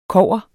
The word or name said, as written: kobber